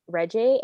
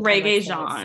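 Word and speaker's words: Regé-Jean: it's if it's Reggie. How do you say his first name? – Reggae Jean. – So wrong. Reggae Jean.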